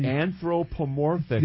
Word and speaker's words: anthropomorphically: you know, we see our Lord, or see our triune God, uh, respond anthropomorphically. I always mess it up, Pastor. Go ahead. Uh, say it for me. – Anthropomorphically.